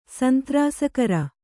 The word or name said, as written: ಸಂತ್ರಾಸಕರ